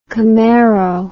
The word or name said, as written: camaro